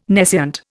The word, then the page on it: nescient: Indian